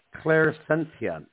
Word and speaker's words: Clairsentience: All right, and you can correct me if my pronunciation is off. Clairsentience. Is that what that is? – You did very well.